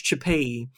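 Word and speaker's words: Chapelli: That's what it's spelled, Chipelli, but it's pronounced Chipei.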